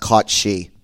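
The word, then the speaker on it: cat sí: Now, in the interest of being me, it's actually pronounced caught she. – Oh. – I'm Irish. It's an Irish cat.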